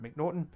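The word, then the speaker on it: M'Naghten: And one last inmate for you, Daniel McNaughton. That's M'Nagten. So I'm going to pronounce it McNaughton.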